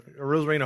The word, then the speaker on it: Arozarena: Arozarena, that's how you say it, right? – Yeah. – Arozarena, homer to right.